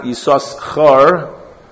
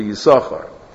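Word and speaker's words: Yisachar (יששכר): That's why in the word Yisachar you have a silent sin and a pronounced sin. The way Yisachar is supposed to be pronounced, correct? It's not Yisachar, like some say, because there is no Nakuda under the second sin. Alpidiktuk, it's supposed to be Yisachar.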